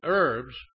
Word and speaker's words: herbs: Proverbs 15 and 17, better is a dinner of herbs, the H is silent, herbs, H-E-R-B-S, where love is.